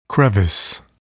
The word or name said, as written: crevice